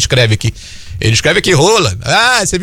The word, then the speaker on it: Haaland: Porque a gente aprendeu que não é Haaland que se fala, é Haaland, né? Que é, o próprio Haaland falou isso, que não, a pronúncia em norueguês é Haaland.